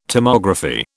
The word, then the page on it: Tomography: British English